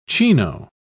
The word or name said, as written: chino